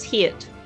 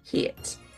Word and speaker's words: Hiett: It's Heet. – How do I spell that? – H-I-E-T-T. – Heet.